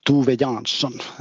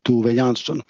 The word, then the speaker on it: Tove Jansson: Yes, actually, her name is pronounced Tove Jansson. That's Swedish name, and it's pronounced a bit different than Finnish name, but Tove Jansson.